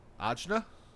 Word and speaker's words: Ajna: A-J-A? – A-J-N-A. – Ajna? – Ajna. – Like, you give me Ajna? – You give me Ajna.